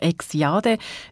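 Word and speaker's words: Exjade: Dann gibt es auch Wortspielerei wie zum Beispiel das Medikament X-Jade. Es schreibt sich X-Jade, wird aber eben X-Jade ausgesprochen. Das braucht man, um zu viel Eisen im Blut zu behandeln.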